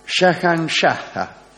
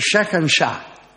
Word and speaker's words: Shahanshah: Ante todo, fue el primer líder musulmán que reivindicó el título de Shahanshah. En realidad es Shahanshah. – Claro. – Tiene una H final que pronuncie demasiado.